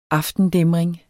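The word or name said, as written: AFTENDÆMRINGEN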